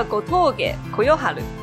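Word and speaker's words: 吾峠呼世晴: 他叫 Gotoge Koyoharu 啊啊啊啊！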